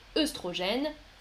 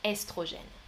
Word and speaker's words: œstrogène: Beaucoup de Français disent œsophage, œstrogène, mais la prononciation traditionnelle, c'est ésophage estrogène.